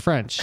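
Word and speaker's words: Ffrench: he has that extra F in his name. So it's F-F-R-E-N-C-H, which is for just French. For French. So I wish people would pronounce it that way.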